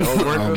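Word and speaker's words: Omertà: Omeka. I mean Omerka.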